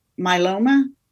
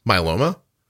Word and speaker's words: myeloma: I don't know about myeloma. Myeloma, is that how you say it? – Myeloma.